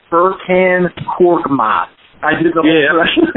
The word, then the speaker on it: Furkan Korkmaz: Furkan Korkmaz. You just call him Fur. No, you, said, you said that right.